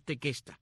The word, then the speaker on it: tequesta: Otros indios de esta área son los llamados tequesta o tecuesta, de acuerdo como lo mencionas, lo pronuncias al inglés o el español, pero es tequesta, tequesta con Q, T-E-Q-U-E-S-T-A,